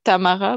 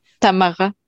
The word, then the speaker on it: Tamara: Yeah, I guess my name is from the French version, so we would say Tamara, but I don't expect you to say it like that.